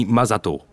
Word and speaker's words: Masato: Mon nom est Akemi Masato. Ça s'écrit M-A-S-A-T-O.